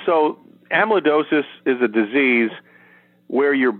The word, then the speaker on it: amyloidosis: Back in, was it 2017, the diagnosis for, and make sure I'm saying it right, amyloidosis? – Amyloidosis, yeah. Mm-hmm.